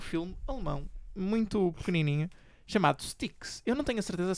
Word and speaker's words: Styx: É um S, um T, um Y e um X. – Deve ser Styx, eu diria Styx.